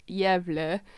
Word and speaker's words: Gävle: It's called the Gävle goat. It's Swedish. So phonetically, it's just spelled G-A-V-L-E, but I looked up the pronunciation. It's Gävle.